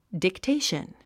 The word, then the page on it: dictation: diktéiʃən; ディクテイション